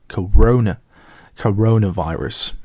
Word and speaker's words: corona: 同时也注意一下它的这个发音重音呢是落在那个 ro 第二个音节 coronavirus。